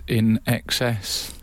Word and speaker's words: INXS: Who knew in excess is pronounced in excess? I've seen it written a million times and never said it aloud.